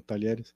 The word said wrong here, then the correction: Talleres: É Talleres, como é que se fala? Como é que fala aí? – Talleres de Córdoba.